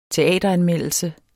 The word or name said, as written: teateranmeldelse